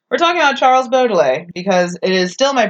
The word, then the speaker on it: Baudelaire: Okay, so I said Baudelaire, like, my whole life, and then Amanda goes, Baudelaire, and I'm like, okay... And then my husband this morning, when I pronounced it correctly, goes, Odele? And I'm like, no.